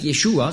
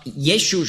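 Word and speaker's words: Jeschu (ישו): Aber sie sagen nicht Jeshua, sondern Jeschu. Also Jeschu schreibt man auf Hebräisch Jod, Shin, das ist bei uns SCH. Und ein Wav, ein V. Das wird ausgesprochen als U, das V.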